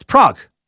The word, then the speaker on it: Prague: He writes, what person over 13 doesn't know how to pronounce the name of the city of Prague? Hail Nimrod. Apparently me, I guess. I can't believe I fucked that one up. It's Prague.